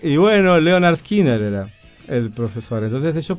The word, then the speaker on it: Lynyrd Skynyrd: Ahí ponen cómo se pronuncia, pero se llama el pronouncer. – Claro, porque es una palabra difícil. – Es Lynyrd Skynyrd. Lynyrd Skynyrd. Ahí está fonéticamente puesto.